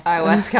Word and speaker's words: ayahuasca: how do you say that word again? – Ayahuasca.